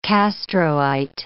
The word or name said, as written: Castroite